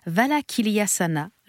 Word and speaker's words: Valakiliyasana: Et là, nous allons faire Valakiliyasana. Je l'ai prononcé Magnifique. du premier coup.